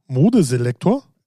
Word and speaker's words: Modeselektor: Spricht man es nicht deutsch aus? – Modeselektor? – Ja. – Auch gut. Ja, klingt für mich so falsch. – Naja, ich glaube, es ist aber tatsächlich richtig. Aber egal, ja.